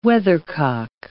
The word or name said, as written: weathercock